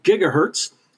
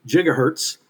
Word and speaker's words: gigahertz: 1,000,000,000 with a B cycles per second would be 1,000,000,000 hertz or 1 gigahertz, or in some places, giga is pronounced with the J sound, so gigahertz. Depends on the country where you go, but I've heard it both ways.